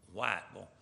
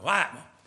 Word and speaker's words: Whiteville: About an hour before you get to Wilmington is a town called Whiteville, North Carolina, or Whiteville is the way they pronounce it over there, Whiteville.